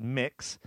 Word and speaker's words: Mx: The prefix MX, pronounced mix, will now be offered to all supporters who want to identify...